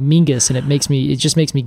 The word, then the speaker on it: Menzies: actual sound of Scottish menzies is actually, it's just there's no letter for it in the right. – English alphabet. So it's actually Mingus, apparently, is how you would pronounce menzies. If you were pronouncing it kind of the traditional Scottish way.